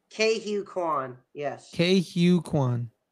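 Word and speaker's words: Ke Huy Quan: K Hugh Kwan. Okay. Not how it's spelled, but how it's pronounced.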